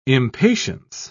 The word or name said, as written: impatience